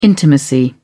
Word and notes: intimacy: British English

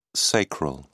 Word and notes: sacral: British English

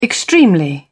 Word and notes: extremely: British English

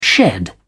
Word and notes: shed: British English

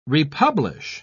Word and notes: republish: British English

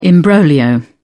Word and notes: imbroglio: British English

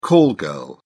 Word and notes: call-girl: British English

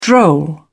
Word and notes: droll: British English

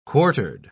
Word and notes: quartered: British English

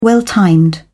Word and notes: well-timed: British English

